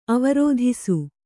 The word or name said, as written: ಅವರೋಧಿಸು